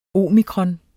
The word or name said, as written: omikron